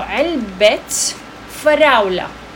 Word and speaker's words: علبة: So علبة فراولة. So we pronounce the ta because there's an ownership and a relationship between the strawberries and the box. They're related.